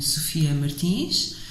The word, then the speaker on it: Sofia Martins: Okay, so my name, as you've heard, is uh, Sofia Martins, or in Portuguese pronunciation, Sofia Martins.